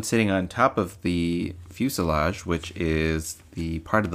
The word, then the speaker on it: fuselage: The fuselage, rhymes with blue garage, is the largest part of the plane.